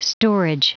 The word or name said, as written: storage